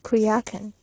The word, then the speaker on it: Coyoacán: On Holy Thursday, we went early in the morning to Coyoacán, now pronounced Cuyacán,